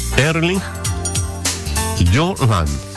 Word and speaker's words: Erling Haaland: Todos decimos Erling Haaland. Dice que la pronunciación cambia absolutamente por completo, porque nosotros lo decimos como se lee, o sea, como se escribe. Y su nombre se pronuncia como Erling Haaland. ¿Por qué? Bueno, le cuento de inmediato. La diferencia en noruego, el idioma, la H inicial se aspira suavemente. Así.